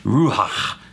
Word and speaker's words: ruach: And so that helps me be like, oh, it's ruach, because the guy says it that way.